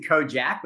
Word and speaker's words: Kojak: It's actually Kojak, but you said it how it's spelled, like everybody else.